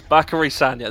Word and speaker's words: Bakary Sagna: Or Sakari Banya. – Bakary Sagna, at least I said it wrong there.